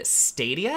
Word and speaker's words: Stadia: Nintendo Switch, Xbox, and Stadia.